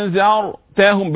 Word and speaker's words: أأنذرتهم: إن الذين كفروا سواء عليهم همزتين محققتين كما قلت أأنذرتهم الراء المفخمة أأنذرتهم بالذال مش بالظاء